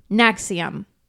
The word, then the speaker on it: NXIVM: It is spelled NXIVM, but it's pronounced...